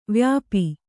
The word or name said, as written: ವ್ಯಾಪಿ